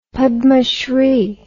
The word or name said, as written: padmashri